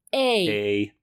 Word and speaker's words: Emil: There's also the interesting choice of not having all the children pronounce Emil's name the same way, even though the movie opens with a pronunciation guide. Like, literally, if you haven't watched this movie, you guys, I've never seen anything like it. It opens, and it's like, Emil and the Detectives, and then it's like, pronounced A-mil. – And then there's an image of a mill.